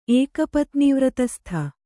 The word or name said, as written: ಏಕಪತ್ನೀವ್ರತಸ್ಥ